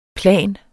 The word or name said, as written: plan